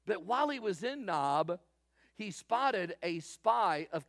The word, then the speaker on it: Nob: It is spelled just like it sounds, N-O-B.